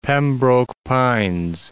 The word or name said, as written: Pembroke Pines